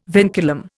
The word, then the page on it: vinculum: Indian